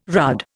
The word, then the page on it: rudd: Indian